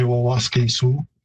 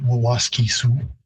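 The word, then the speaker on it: Waskesiu: So the pronunciation isn't too far off. In Plains Cree, I'd say Waskasu, and in Woods Cree, which is the territory where it's located, Waskisu.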